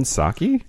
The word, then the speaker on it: Psaki: Jen Psaki? – Yeah. Is that her last name? – Yeah. – I thought it started with a P. – There's silent P's in our language, Kyle.